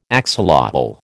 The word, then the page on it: axolotl: American English